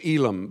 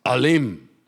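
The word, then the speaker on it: Elim: So I'm going to say it's Elam, but it's Alim.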